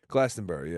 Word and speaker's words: Glastonbury: Glastonbury, yeah.